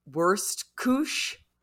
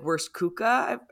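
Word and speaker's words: Wurstküche: I can never pronounce it correctly. And I welcome somebody correcting me. I've been corrected many times and it doesn't stick. It's called like Wurst Kuch. It's W-U-R-S-T-K-U-C-H-E. Like Wurst Kuka.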